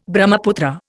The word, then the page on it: Brahmaputra: Indian